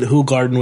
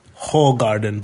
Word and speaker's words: Hoegaarden: Yeah, because last week I said I thought that Hoegaarden was pronounced Hoegaarden, but it's actually pronounced Hoegaarden, or close enough to that.